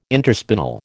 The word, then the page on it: interspinal: American English